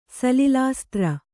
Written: ಸಲಿಲಾಸ್ತ್ರ

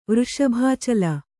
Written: ವೃಷಭಾಚಲ

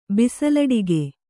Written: ಬಿಸಲಡಿಗೆ

